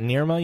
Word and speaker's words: Nirma: Is it Nirma University? How do you say that? – Yes, that's correct.